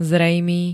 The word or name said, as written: zrejmý